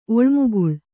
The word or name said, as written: ಒಳ್ಮುಗುಳ್